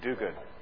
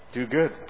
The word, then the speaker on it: Duguid: How would you say that? – Duguid. – Duguid? – Yeah. – Is it really? – Yeah. – Duguid.